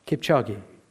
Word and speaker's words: Kipchoge: Kipchoge.